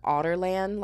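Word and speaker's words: Adarlan: It's literally like otter, but like Otterland, like Ireland, and otters, Otterland, like Otterland.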